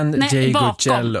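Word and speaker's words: Ndegeocello: Ngeyoe. Michelle Ngeyoe. – Nej, Ngeyocello. Ngeyocello, ja. Kanske.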